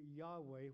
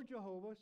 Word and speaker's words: Yahweh: So we can always say Yahweh or Jehovah. Sometimes it's pronounced that way. Yahweh, Jehovah, the same name, just pronounced differently. Because in the Hebrew language, there are no vowels. It's just YHWH. And so the vowels are provided. Yahweh, Jehovah, the W can be like a V sound.